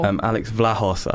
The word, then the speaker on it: Alex Vlahos: We've got Alex Vlahos. How do you say his name? I'm not sure. – Alex Vlahos. – Vlahos.